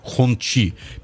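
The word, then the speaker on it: Hongqi: Да, согласно популярной у китаистов в России транскрипционной системе Палладия, название читается как Хунцы. В представительстве компании настаивают на Ханчи, а в действительности сами китайцы произносят имя машины примерно как Хунчи.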